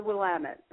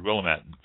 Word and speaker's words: Willamette: That is the Willamette. It's right. It's that. Not to interrupt you, Portia, but Willamette. You pronounce it. I've always heard, and I've always said Willamette, but it's Willamette.